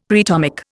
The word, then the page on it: preatomic: Indian